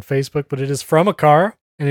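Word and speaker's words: Aqara: And then they finally say it's pronounced Acara.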